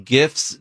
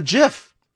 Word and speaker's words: GIF: But no, but the person who created the programming for that says that it's a GIF. – Here's the thing. GIF is short for graphic interface. That's the caption. So it's a hard G.